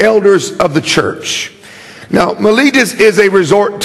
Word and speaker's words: Miletus: how do you say that, Calvin? Miletus. I've been screwing that name up all week long. Miletus, verse 17.